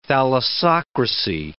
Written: thalassocracy

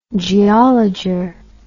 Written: geologer

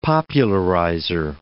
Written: popularizer

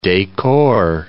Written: decor

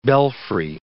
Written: belfry

